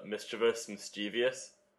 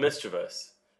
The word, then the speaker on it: mischievous: My brothers always get caught up on the mischievous. Because it's spelled mischievous, but you can pronounce it mischievous if you'd like.